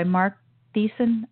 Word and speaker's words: Thiessen: And it's written by Mark Thiessen, I believe is the way that you pronounce the name, published today, just this morning.